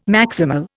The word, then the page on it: maximal: Indian